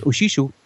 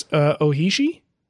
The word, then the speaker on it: Ohishi: because Oshisho. Or supposedly longer lasting than Norton's. How come I have a feeling I pronounced that incorrectly? – It's Ohishi? – Sure. – Okay. Sure. – That's how it's spelled.